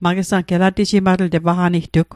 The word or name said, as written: elatejimatl